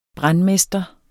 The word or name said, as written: brandmester